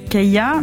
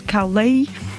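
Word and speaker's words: Caillat: Alors je dirais Kaya, mais apparemment ça se prononce Carly quand on regarde sur internet.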